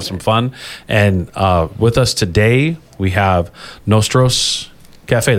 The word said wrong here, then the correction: Nosotros: Did I pronounce that correctly? – Yes, Nosotros Cafe. – Nosotros.